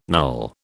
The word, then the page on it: null: British English